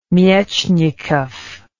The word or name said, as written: mechnikov